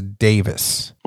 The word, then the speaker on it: Daviess: So before we start drinking this, I want to let you know, even though it's spelled D-A-V-I-E-S-S, it's Davis.